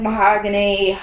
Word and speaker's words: Mahogany: Is people will butcher the fuck out of my name and it's spelled exactly how it sounds. Mahogany.